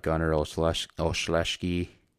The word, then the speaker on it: Gunnar Olszewski: But I think they did a lot, and they, you know, they got Gunnar Olszewski.